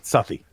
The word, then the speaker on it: Southie: I think it's Southie. – Yeah, it's oh, okay. Southie.